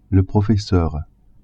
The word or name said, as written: professeur